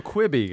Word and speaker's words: Quibi: Now, I noticed you feel like you pronounce it Queeby. I'm a Quibby guy. – Well, let's call the whole thing off. No, I think I am. I think I am mispronouncing it.